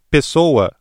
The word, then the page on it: pessoa: ブラジルポルトガル語